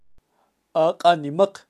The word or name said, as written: ak animaq